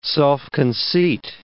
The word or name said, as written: self-conceit